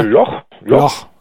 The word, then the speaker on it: Loch: Und es ist ja Loch Ness, wie spricht man das eigentlich aus? Du bist ja quasi Halbschotte. – Nicht nur quasi, sondern also biologisch belegt. Ja, ja. Loch, okay.